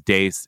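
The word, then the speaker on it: Deace: Now, I suspect his name is pronounced Deese. He insists it's Dace.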